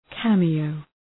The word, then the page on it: cameo: ‘kæmı,əʋ